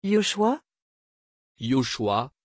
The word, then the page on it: Yoschua: French